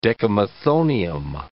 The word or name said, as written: decamethonium